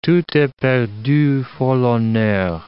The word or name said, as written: tout est perdu fors l'honneur